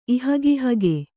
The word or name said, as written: ಇಹಗಿಹಗೆ